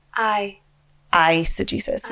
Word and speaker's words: eisegesis: That is eisegesis. – Yes. – Eisegesis. I see what they did there. – It is spelled with an E. Eisegesis. – Yeah. But it means... It does sound like it.